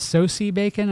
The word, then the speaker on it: Sosie Bacon: I don't think, I don't guess we're going to get, is it Sosie Bacon? Am I saying her name correctly? – Yeah.